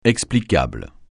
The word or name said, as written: explicable